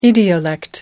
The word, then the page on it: idiolect: American English